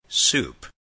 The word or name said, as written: soup